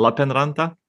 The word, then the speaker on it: Lappeenranta: How do you pronounce it? Lappeenranta? – You do it very well.